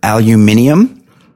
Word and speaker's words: aluminium: So, you know, both aluminium and aluminum are correct because the guy that invented or discovered aluminium, he named it aluminium. And then after it was named that, he changed the name to aluminum. So both pronunciations actually correct. Aluminium and aluminum.